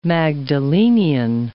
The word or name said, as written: Magdalenian